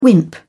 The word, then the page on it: wimp: British English